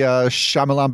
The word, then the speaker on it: Shyamalan: I was still on the Shyamalan bandwagon.